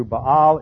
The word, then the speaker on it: Baal: But another thing is going on here, and that is that the state religion is now Baal worship, and Baal, as it's pronounced in the Hebrew, Baal is the god, the storm god.